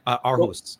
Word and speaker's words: Aarhus: I always pronounce it the Danish way because that's a city in Denmark, right? – How do you pronounce that? – Aarhus.